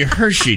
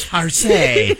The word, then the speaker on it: Hershey: I believe it to be Hershey, dear. Oh, Hershey. I thought it was perhaps Harsay.